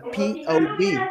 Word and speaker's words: POV: Y veo un video que dice, no, es que no se pronuncia pop, se pronuncia POV, que significa Point of View.